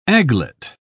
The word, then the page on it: aglet: British English